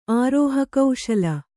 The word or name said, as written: ಆರೋಹಕೌಶಲ